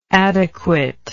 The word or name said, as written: adequateness